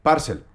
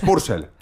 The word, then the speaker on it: Purcell: Por cierto, Nicholas Jackson, yo estaba harto de Parcel. Dice usted mal Parcel. Es Purcell. Purcell. Dice usted mal. Y ya le dije, ¿cómo demonios se dice esto en este Manchester? Y él me dijo, Purcell.